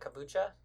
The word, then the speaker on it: kombucha: I've been trying to get into kombucha. How do you say it? Kombucha? – Kombucha.